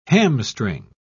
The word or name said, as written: hamstring